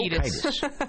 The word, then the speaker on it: Kiedis: I always mispronounce his name, but I think I got it right that time. – Nope. Kiedis. – All right. And it's really misspelled. It should be C-E-I, so that the accent would be on the E, vowel after.